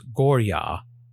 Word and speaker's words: Goryeo: Romanization varies wildly between East Asian languages, even those as geographically close as Japan and Korea. A closer pronunciation is Gorya, which I shall be using from this day forward.